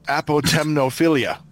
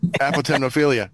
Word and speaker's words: Apotemnophilia: My last one is a 10-minute movie called, see if I can pronounce this right, Apotemnophilia. – Sure. – A-P-O-T-E-M. – That's right. That's absolutely right. – Apotemnophilia.